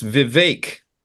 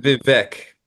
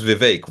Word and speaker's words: Vivek: The one of the main things I took from that is it's Vivek. Mm-hmm. Not Vivek. – Yeah, he says rhymes with cake.